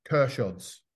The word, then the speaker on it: Kershods: Apologize, Churchods. – No, Kershods. – I'll take your word for it. Okay. – As in K-E-R. Think of it K-E-R, Kershods.